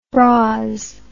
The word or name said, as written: bras